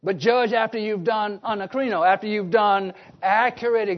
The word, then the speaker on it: anakrino: phonetically as we spell A-N-A-K-R-I-N-O, anacrino.